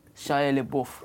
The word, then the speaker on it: Shia LaBeouf: How do you pronounce his name? – Shia LaBeouf.